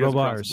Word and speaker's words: Robards: The first seed over there is Jason Robards. Did I pronounce that correctly, Pete? – And, he doesn't pronounce the D. – Oh, he Robards. Doesn't pronounce D.